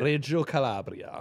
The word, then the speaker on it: Reggio Calabria: Reggio Calabria.